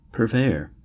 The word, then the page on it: purveyor: American English